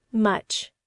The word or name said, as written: much